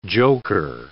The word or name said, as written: joker